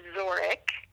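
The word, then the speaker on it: Wzorek: First name is Christine, C-H-R-I-S-T-I-N-E. Last name is pronounced Zorek, but you'll want to write this down. It's W-Z-O-R-E-K.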